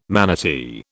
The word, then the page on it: manatee: British English